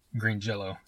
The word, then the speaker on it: Green Jelly: They changed it to Green Jelly, and then they said, but it's pronounced Green Jell-O. So even though it's spelled Green Jelly, it's still pronounced Jell-O. – They could have spelled it J-E-L-L-O-W or some shit.